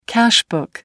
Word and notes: cash book: American English

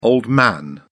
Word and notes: old man: British English

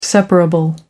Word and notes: separable: American English

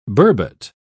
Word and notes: burbot: British English

